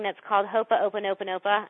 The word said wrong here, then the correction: Ho'oponopono: I don't think that's actually how you say it, but Ho'oponopono. – Yes, there you go. And she knows how to say it.